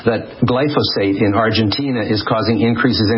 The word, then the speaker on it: glyphosate: He claims that Roundup has as its main ingredient glyphosate. I believe that's how you pronounce it. – Sure.